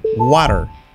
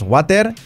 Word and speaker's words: water: Water, agua. Cuidado la pronunciación, que no es water, es water, water. Se podría decir water, water, pero ya sabemos que la T es como una R suave.